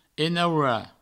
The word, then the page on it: enaurar: enaura